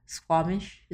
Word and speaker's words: Squamish: So they were in a park, Squamish.